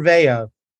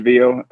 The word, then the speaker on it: Veo: Is it Veo or Veo? – You know, it derives from the Spanish Veo, where, you know, C2C. So we pronounce it, you know, both ways, to be quite honest with you.